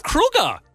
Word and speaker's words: Kroeger: Chad Kroeger is how you actually pronounce his name. It is Kroeger, not Kroeger.